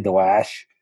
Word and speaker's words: Daesh: So Daesh, or as the Iraqis say, Daesh, is how you say ISIS.